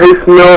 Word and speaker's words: اسم: الالف ده لا ينطق عند الوصل. سبح حضرتك يبقى ايه؟ سبح اسم